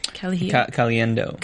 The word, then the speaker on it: Caliendo: Like he's pissing off, Calihio. How do you say it again? – Caliendo. – Caliendo. Officer Caliendo,